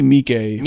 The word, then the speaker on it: Miike: Miike? How do you say his name? Takashi Miike.